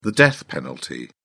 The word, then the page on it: death penalty: British English